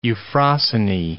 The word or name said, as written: Euphrosyne